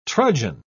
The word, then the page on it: trudgen: British English